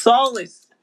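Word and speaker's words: Salas: It's a weird word, just like my last name, which is no, it's Solis.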